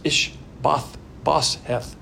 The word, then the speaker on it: Ish-bosheth: Now he's the son of Saul, and his name's difficult to pronounce, but it's Ish-bosheth.